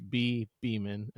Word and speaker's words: Bhi Bhiman: Um, but it's B H I, and that's pronounced B. It's like a silent H, and the I is kind of an E sound. So it's Bhi Bhiman, and then my last name is B H I M A N.